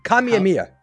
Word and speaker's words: Kamehameha: Do you hear people calling this thing the I've heard Kamehameha mispronounced and butchered on fucking TikTok for people who don't play Dragon Ball, and it drives me. Kamehameha.